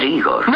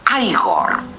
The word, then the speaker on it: Igor: Usted debe de ser Igor. No, se pronuncia Igor.